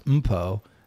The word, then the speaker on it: Mpho: And it's pronounced Mpo Ngope.